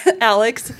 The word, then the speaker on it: ALEKS: I do get the question a lot about how it's pronounced, so thank you for pronouncing it ALEKS, like the name. – Yeah. – ALEKS, A-L-E-K-E-X, but it's the ALEKS Placement Preparation and Learning Assessment.